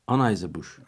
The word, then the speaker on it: Anheuser-Busch: Mesela NBC News'un haberine göre geçtiğimiz çarşamba günü dünyanın en büyük bira üreticilerinden Anheuser-Busch, yanlış telaffuz ediyorsam düzeltin tabii ki. – Anheuser-Busch.